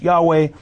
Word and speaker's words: Yahweh: And as we consider the divine name in Exodus 3:14, it's Jehovah, sometimes it's pronounced Yahweh.